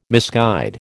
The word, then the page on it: misguide: American English